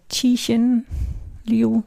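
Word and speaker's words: Cixin Liu: Ähm, ich habe gerade letztens äh, angefangen, obwohl diese Bücher schon älter sind, von, von Qixin Liu. Spreche ich das richtig aus? – Ja, ich denke Qixin Liu, ja würde ich es jetzt aussprechen.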